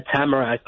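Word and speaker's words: Tamarac: Did I pronounce the name of your city correctly? – Yes, Tamarack, Florida.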